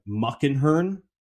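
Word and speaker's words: Muckenhirn: The agreement for this was the Mariners will get back a reliever that the Mets DFA'd, Zach, let's try and get this pronunciation, Muckenhern. Does that sound right? – That sounds pretty right.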